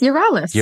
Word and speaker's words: Yerales: It's Yerales, yes.